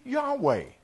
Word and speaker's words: Yahweh: All we did was we found out finally, the manuscripts helped us to understand that the way we used to pronounce the name Jehovah was really Yahweh. Y-A-H-W-E-H. Yahweh.